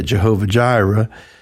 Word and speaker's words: Jehovah Jireh: Of course, that's English version. I can't say it right in Hebrew, but anyway, we say it Jehovah Jireh.